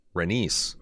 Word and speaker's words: Rhaenys: Almost pronounce it wrong. Rhaenys.